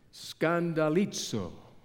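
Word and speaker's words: skandalizo (σκανδαλίζω): In the Greek, it is pronounced this way, skandalizo.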